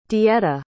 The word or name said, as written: Deetta